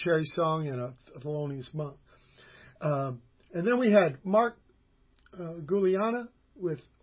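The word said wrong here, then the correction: Guiliana: Juliana, I should say. Yeah. – Shit. Yep. – Right. They have the soft G, giallo for yellow. – Yeah, that Italian pronunciation.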